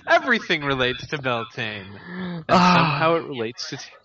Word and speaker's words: Beltane: Yeah, he's pronouncing it Beltane, and I'm pronouncing it Beltania. This is simply a stylistic thing. It's spelled Beltane.